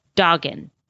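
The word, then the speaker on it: Dogen: as well as Dagon. Did I say that right? – I think that's right, yeah.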